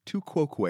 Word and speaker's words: tu quoque: And it's called tu quoque. – Mm-hmm. – So it's a famous Latin. – Exactly. You know. So it's T-U is the first word, then Q-U-O-Q-U-E. Tu quoque.